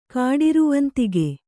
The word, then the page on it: ಕಾಡಿರುವಂತಿಗೆ: Kannada